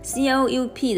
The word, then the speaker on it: coup: C O U P 的 P 是不发音的，这个字的意思是政变，所以他是说，在缅甸一年后，在政变一年后，他们在二月一号呢有一场安静的罢工。